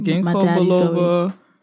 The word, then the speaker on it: Ginkgo biloba: I am drinking Ginkgo biloba. I don't know if I'm saying it right. – That is how you say it. – Yeah. I'm drinking Ginkgo biloba chamomile.